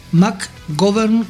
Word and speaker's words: McGovern: McGovern. McGovern, como si fuera govern, gobierno. – Como gobierno, claro, pero con B corta, ¿sí? – Exacto.